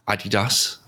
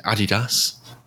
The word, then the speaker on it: Adidas: And they've got a generation Adidas. I'm going to say Adidas, not Adidas.